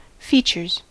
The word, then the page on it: features: American English